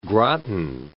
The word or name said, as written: Groton